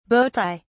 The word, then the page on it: bowtie: British English